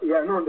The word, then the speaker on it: Yanun: They say Yanun. It's pronounced Yanun, depending on the dialect of what galaxy you're in, the pronunciation changes.